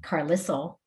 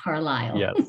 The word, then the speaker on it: Carlisle: And I realized after I looked it up that even though it's spelled like Carlisle, it's pronounced Carlisle.